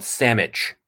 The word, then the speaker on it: Salmich: It's called Salmon. – What's it called? – Salmon. – Salmon, yeah, just like. Sorry, called Samich. Okay. It's called Samich. But it's spelled S-A-L-M-I-C-H.